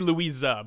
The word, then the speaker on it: Louise: They say Louisa, but yeah. – Louisa. – That's just how they pronounce it. Yeah, it's spelled Louise, like Louis with an E.